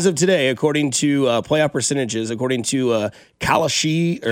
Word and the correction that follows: Kalshi: I think it's pronounced Kalshi, K-A-L-S-H-I.